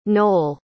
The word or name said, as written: nole